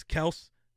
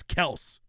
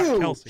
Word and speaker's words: Kelce: Yeah, he came out and said that's how you're supposed to say it. It's Kelce, not Kelce. – He's wrong. – No, it's Kelce, bro.